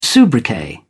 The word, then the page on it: soubriquet: British English